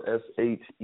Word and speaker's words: Shea: It sounds just like it's spelled, Shea, Shea B. Ross, Rios.